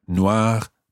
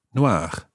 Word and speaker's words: noir: With noir, the E being added onto the end doesn't make any difference to the pronunciation of the masculine form, noir, noir. They sound exactly the same.